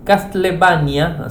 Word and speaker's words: Castlevania: a ver si lo pronuncio bien, Castlenavia eh, no, eh, Castlevania, así lo dije bien, Castlevania, eh, Symphony of the Night, con lanzamiento sorpresa tanto en Android como en iOS.